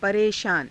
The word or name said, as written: pareshaana